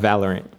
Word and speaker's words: Valorant: Am I pronouncing that right? Or is it Valorant? – Valorant.